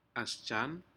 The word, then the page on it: asjan: Aneityum